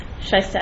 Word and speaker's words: Shaista: B-O-R-G-E-R-S is my last name. – Shaista Ahmed, S-H-A-I-S-T-A-A-H-M-E-D with the FCIC. – Henry Albulescu, A-L-B-U-L-E-S-C-U, with Standard & Poor's.